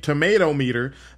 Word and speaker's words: tomatometer: Also, Screen Thrill posted that Black Panther Wakanda Forever, the tomato meter, it's hard to say, the tomatometer, I believe is how it's pronounced.